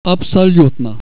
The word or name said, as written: absolut